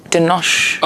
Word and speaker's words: Denosh: I really like how you guys say my name, the emphasis on the shh, Denosh. – Oh, is it normally a little bit more... – You know, Americans are very, like, open with the ah, Denosh, so you put the emphasis on the shh, which I like.